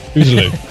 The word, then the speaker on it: Uzalu: Did I say it right this time? Uzalu? – Close enough. Uzalu.